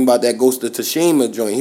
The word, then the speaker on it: Tsushima: It's Tsushima. – Oh, Tsushima? – Yeah. The T is silent.